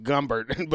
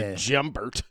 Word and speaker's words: Jumbert: Because my name is Gumbert, but it's spelled Jumbert, and every asshole calls you Jumbert.